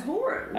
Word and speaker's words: Horan: It's Horan.